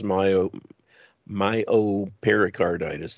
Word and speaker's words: myopericarditis: He also criticized the study authors for characterizing some of the heart conditions that children experienced, such as myopericarditis, that's the way it's pronounced, as mild.